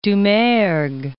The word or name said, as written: Doumergue